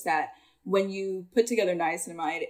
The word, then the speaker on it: niacinamide: Niacinamide.